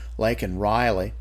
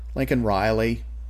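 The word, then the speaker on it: Laken Riley: "Lincoln Riley, Biden said, garbling her first name, an innocent young woman who was killed by an illegal.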